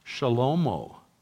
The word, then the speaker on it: Shlomo: It's not pronounced Solomon at all. It's pronounced Shlomo, from the Hebrew word shalom, which means peace.